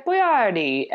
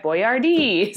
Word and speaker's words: Boyardee: And then in the late 70s, his last commercial, he's like, "It's me, Chef Boyardee." So he like, even he just got swept up in the American pronunciation of his Italian name.